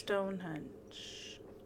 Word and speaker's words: Stonehenge: Stonehenge. – I always want to say hedge. – Stonehenge. – It's not. It's Stonehenge. Henge. It's NG.